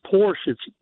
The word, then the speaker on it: Porsche: Everybody calls it Porsche. That's incorrect. It's Porsche. The Porsche. – Car, it's a two-syllable word.